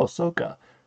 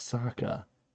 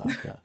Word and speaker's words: Sokka: culture, and I see the name, and I've always been like, oh, Soka. And I've had to be like, no, it's Sokka. It's pronounced Sokka.